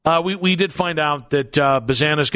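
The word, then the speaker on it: Bazzana: We also found out, so Travis Bazana is actually how we say the name.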